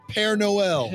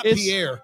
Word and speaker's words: Père Noël: Père Noël. – Père Noël. – Not Pierre.